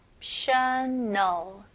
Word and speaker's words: exceptional: Exceptional. Exceptional. 네, 여기서 이 X로 시작하지만 exceptional보다는 exceptional. I 발음으로 주로 많이 발음합니다.